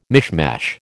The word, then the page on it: mishmash: American English